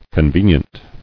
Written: Conveniently

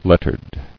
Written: Lettered

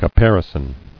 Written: Caparisoned

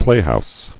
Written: Playhouse

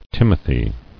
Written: Timothy

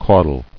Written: Caudled

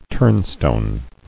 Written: Turnstone

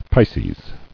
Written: Pisces

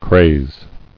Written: Crazing

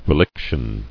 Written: Reliction

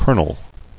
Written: Colonelship

